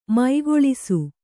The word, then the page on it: ಮುಕ್ಕುಳಿಸು: mukkuḷisu